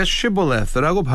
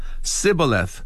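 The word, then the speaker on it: Shibboleth: 그러니까 Shibboleth라고 발음을 했다면 살았을 것도 Sibboleth 이렇게 발음을 하면 죽였다는 것이지요. Jephthah judged Israel for 6 years before he died.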